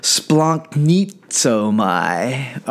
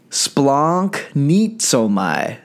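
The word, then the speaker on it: splanknitzomai (σπλαγχνίζομαι): The word is splanknitzomai. All right, say it with me. Are you ready? Splanknitzomai.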